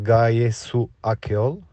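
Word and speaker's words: Gaye Akyol: Donc ça se prononce Gaillé sous Akiol.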